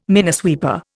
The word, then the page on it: minesweeper: Indian